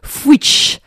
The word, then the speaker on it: Fois: le mot occitan qui s'écrit foi, F-O-I-S, et qui se prononce fuich.